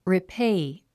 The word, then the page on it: repay: ripéi; リペイ